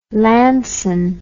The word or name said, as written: lanson